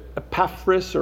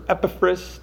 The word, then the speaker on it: Epaphras: You know, that's a tough name to say, and so they shorten it a little bit to Epaphras or Epaphras, depending on how you pronounce it. Either one's okay.